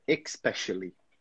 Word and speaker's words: especially: Good, good, that's it, especially. But back there, you said especially. So we don't have that X sound here, simply especially.